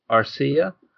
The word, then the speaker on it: Arcia: Arcia.